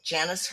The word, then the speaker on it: Janice: Janice Hurley,